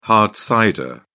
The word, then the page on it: hard cider: British English